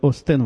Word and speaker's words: OS X: А, я проверял эту программу на, Uni, на Linux, на своих и на OS X, которую, как правильно сказали, надо называть OS X в одном из подкастов, но неважно.